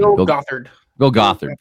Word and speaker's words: Gothard: And I had never even heard of Bill Gothard either. I can't even say his name. – Bill Gothard.